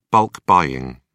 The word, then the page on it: bulk buying: British English